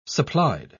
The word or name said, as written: supplied